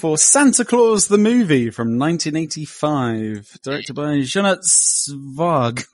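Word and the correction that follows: Jeannot Szwarc: It's Jeno Swark.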